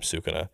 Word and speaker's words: Sukuna: Ra- Rauman, Rauman Sukana. They really just Sukuna. call Sukuna. Uh, they usually just call him Sukuna, of whom Yuji becomes the host to.